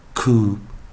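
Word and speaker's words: coup: 首先第一个 c c o u p 啊，这个 p 结尾的 p 不要发音，它是 silent，所以我们是念 coop coop coop，不是 coop，是 coop coop coop。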